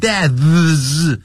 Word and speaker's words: deaths: 是无声的 death 然后发一个 s 所以呢就这样 deaths 这样发音就可以了 deaths 不要念成 deaths